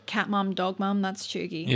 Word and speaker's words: cheugy: And the code word for this week is Chuggy. – Yeah, perfect. – To clarify, it's spelt Cheugy.